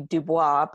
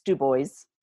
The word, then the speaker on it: Dubois: And Steve's plan for that day was to drive with his yellow lab named Jones, J-O-N-Z, I know, to Dubois. And I have to say, this is how you pronounce it. It's a French word, and normally it would be Dubois, but in Wyoming, it's Dubois.